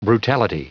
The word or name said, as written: brutality